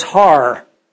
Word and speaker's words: Har: In Hebrew, hill is Har. So Har Megiddo is Armageddon.